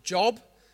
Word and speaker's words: Job: And it's spelled J-O-B. So, if you look, find the book of Job, it's actually pronounced Job.